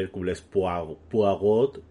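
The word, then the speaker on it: Hercule Poirot: Y pues eso, peli de Kenneth Branagh, que dirige y protagoniza otra vez, haciendo de Hércules Poirot, porque hay que pronunciarlo así, él lo dice, lo deja claro.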